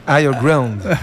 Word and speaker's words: Higher Ground: Toi-même, tu sais, il est 21h26 avec Higher Ground. Je ne sais pas si je le dis bien. – Higher Ground.